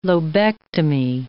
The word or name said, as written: lobectomy